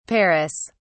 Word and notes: paras: British English

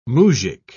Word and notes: moujik: British English

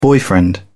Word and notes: boyfriend: British English

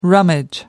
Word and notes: rummage: American English

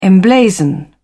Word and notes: emblazon: British English

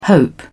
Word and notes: hope: British English

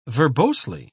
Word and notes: verbosely: British English

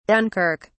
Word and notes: Dunkirk: British English

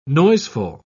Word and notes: noiseful: British English

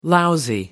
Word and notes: lousy: American English

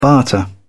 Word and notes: barter: British English

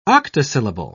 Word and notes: octosyllable: British English

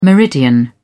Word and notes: meridian: British English

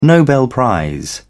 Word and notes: nobel prize: British English

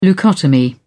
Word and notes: leucotomy: British English

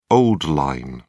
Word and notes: old-line: British English

